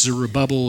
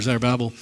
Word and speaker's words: Zerubbabel: depending on which emphasis and syllable you choose. It's Zerubbabel or Zerubbabel.